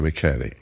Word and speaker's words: Michele: Alessandro Michele. – What's his name? – Alessandro Michele. – Michele? An Irish name? – No, M-I-C-H-E-L-E, but like Michelle, but they pronounce it.